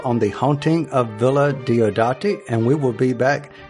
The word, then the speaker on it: Diodati: And Lee, am I saying that correctly? Is it? – As far as I know, that's the way I've always said it, Diodati.